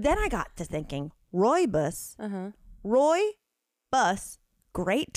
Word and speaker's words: Rooibos: It's Rooibos. – Rooibos, and it's South African. – I thought it was Rubus. Like, look at the way it's spelled. – Yeah, that looks like a kid's show name. – Rubus. I thought it was Rubus. Apparently, it's Rooibos.